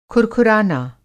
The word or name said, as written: खुरखुराना